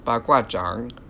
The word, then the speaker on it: baguazhang: Now, in the bamboo grove scene, of course, enter Ying Li with her baguazhang. So that is the proper pronunciation. I'm sorry for every time I said it before. Zhang has a third tone on it, so it's baguazhang, not baguazhang, which, if you know Chinese, this makes a difference.